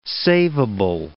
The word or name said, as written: saveable